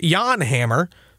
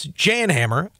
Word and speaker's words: Jan Hammer: He maintained it was Jan Hammer because he is Czech. I said, no. It's Jan Hammer. I looked it up. You can mark this down as truth. It's Jan Hammer.